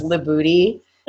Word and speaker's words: Libutti: His last name is Libuti, and it's spelled Libutti. I did, just as a side note, keep my own last name. – Libuti.